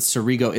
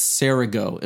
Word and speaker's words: Cerego: And by the way, I apologize, I think I pronounced it Cerego. It's Cerego. Is that right? – It is.